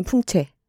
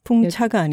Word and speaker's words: 풍채: 그리고 여자는 풍채. 저희가 여러 번 강조해온 바 있습니다. 풍차가 아니죠.